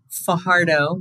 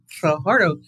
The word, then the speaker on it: Fajardo: This is your host, recording live from Fajardo, which I just understand I'm not saying it right. I'm supposed to roll the R, and I don't know how to. Fajardo.